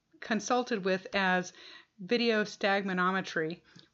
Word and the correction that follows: nystagmography: which is not right. And so Jim's going to talk about that in detail right now. – Oh my God, can I remember how to pronounce it? Video nystagmography is what VNG stands for, which is why we always call it VNG.